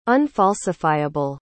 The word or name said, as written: unfalsifiable